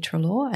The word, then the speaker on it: Treloar: Jeannie Treloar, and that's spelled J-E-A-N-N-E, Treloar, T-R-E-L-O-A-R.